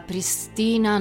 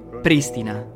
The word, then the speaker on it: Pristina: L'ho corretta. Pristina, non pristina.